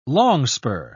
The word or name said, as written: longspur